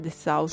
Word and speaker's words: Dessau: Si dice Dessau, sì, sì ok.